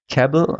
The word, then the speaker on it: Cabell: Cabell wird so ausgesprochen wie Rebel.